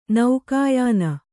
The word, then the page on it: ನೌಕಾಯಾನ: Kannada